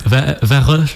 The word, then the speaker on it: Værøy: Alors, Værøy. – C'est ça. Værøy. La prononciation.